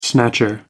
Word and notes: snatcher: American English

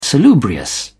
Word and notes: salubrious: British English

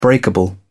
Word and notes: breakable: British English

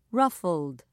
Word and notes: ruffled: British English

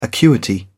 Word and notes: acuity: British English

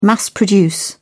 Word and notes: mass produce: British English

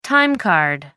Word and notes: time card: American English

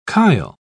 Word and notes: chyle: British English

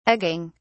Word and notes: egging: British English

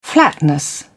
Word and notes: flatness: British English

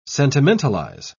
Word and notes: sentimentalize: British English

